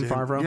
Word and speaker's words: Favreau: First off, isn't that how you say his name, Favreau? – Yeah, I don't care.